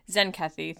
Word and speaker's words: Zenkethi: He's there to celebrate this promotion, but he's also there to tell Sisko that a coup has taken place on the Zenkethi.